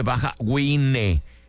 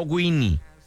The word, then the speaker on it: Baja Wine: Se escribe Baja Wine, no Winnie.